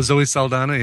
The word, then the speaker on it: Saldana: Oh, Zoe Saldana.